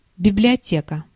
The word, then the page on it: library: /ˈlaɪ.ˌbɹɛɹ.i/; /ˈlaɪ.bɹə.ɹi/; /ˈlaɪ.bə.ɹi/; /ˈlaɪbɹi/; /ˈlaɪˌbɛɹi/; /ˈlaɪ.bɚ.i/